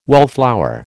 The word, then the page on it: wallflower: American English